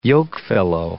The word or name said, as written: yokefellow